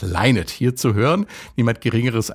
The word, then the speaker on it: Lynott: Well, my next guest this morning is Philomena Lynott. And that's how you pronounce it, as she just told me.